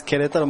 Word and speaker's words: Querétaro: So my hometown is Querétaro, Mexico.